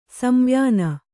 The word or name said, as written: ಸಂವ್ಯಾನ